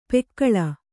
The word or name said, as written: ಪೆಕ್ಕಳ